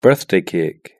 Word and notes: birthday cake: British English

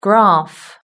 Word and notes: graph: British English

